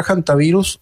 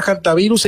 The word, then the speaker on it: hantavirus: Creo que se refiere a la palabra hantavirus, y la palabra hantavirus no es una palabra en castellano, por eso se pronuncia como se pronuncia. Es una palabra que apareció en Corea hace bastante tiempo. Créame que me he tomado la tarea de buscar. La pronunciación se podría pronunciar también con la H muda, por supuesto, y también es válida la pronunciación haciendo el énfasis en la primera sílaba con la letra J de Janta, aunque también es válida la forma antivirus.